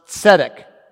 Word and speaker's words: tzedek: You don't have to know how to spell it, but it's pronounced tzedek, which means to be just, to be right, to be in line, to be in the right.